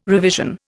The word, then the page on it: revision: Indian